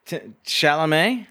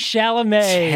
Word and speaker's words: Chalamet: Timothée Charlemagne? What's his name? – Chalamet? – Timothée Chalamet.